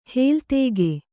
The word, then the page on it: ಹೇಲ್ತೇಗೆ: hēltēge